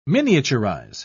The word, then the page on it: miniaturise: British English